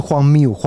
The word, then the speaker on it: 荒谬: 是的，我这边稍微纠正一下低嫂的读音是荒谬荒谬啊，因为一定会有观众啊写信过来这样子。